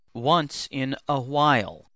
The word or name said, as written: once in a while